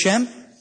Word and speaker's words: Schempp: Frequently just referred to as the Shemp case. His name is spelled S-C-H-E-M-P-P.